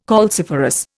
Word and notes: calciferous: Indian

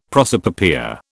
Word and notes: prosopopoeia: British English

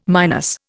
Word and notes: minus: Indian

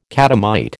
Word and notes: catamite: American English

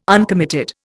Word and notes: uncommitted: Indian

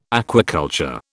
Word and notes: aquaculture: British English

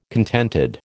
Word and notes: contented: American English

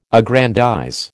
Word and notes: aggrandize: American English

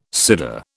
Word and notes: cyder: British English

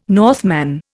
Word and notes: Northman: Indian

